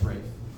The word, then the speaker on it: Ralph: It's Ralph Vaughan Williams, but... – It's pronounced Rafe.